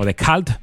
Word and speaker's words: The Cult: Prácticamente bajo la lluvia que nos está acompañando ahora en la capital vizcaína, este Rain de The Cult, o The Cult, para pronunciarlo bien.